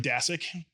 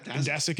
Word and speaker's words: Daszak: But so how do you pronounce his last name? – Daszak. – See, I keep saying Daszak for some reason, but everybody has a different. But it's Daszak. – Daszak is how he pronounced it, I believe.